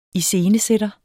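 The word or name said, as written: ISCENESÆTTERENS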